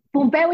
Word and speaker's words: Pompeu: ¿Cómo se pronuncia? ¿Pompú? – Pompeu, y Fabra, es catalana.